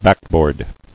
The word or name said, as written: Backboard